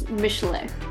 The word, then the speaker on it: Michelin: And yeah, so, and if you want to get fancy, you have to pronounce it like the original French pronunciation, which is Michelet.